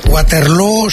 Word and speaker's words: Waterloo: Bueno, lo primero, Waterloo se pronuncia igual que Feijó.